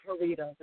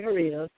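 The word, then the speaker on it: Haritos: You can also go to my Facebook page, which is Andrea Jaritos, and that last name is spelled H as in Harry, A-R-I, T as in Tom, O, S as in Sam, Andrea Jaritos.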